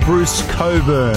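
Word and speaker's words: Bruce Cockburn: It's pronounced Bruce Coburn. Not spelled like that, though.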